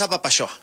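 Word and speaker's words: Apapaxoa: Entonces, la invitación abierta a Papashoa, se escribe con X, Juan, ¿eh? Claro. A Papashoa, se pronuncia como si fueras de Chihuahua, pero es a Papashoa.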